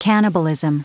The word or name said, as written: cannibalism